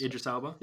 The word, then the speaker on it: Idris: That's actually Idris Elba's voice. – I did know that, yeah. – Am I pronouncing that correctly, Idris? – Idris Elba, yeah.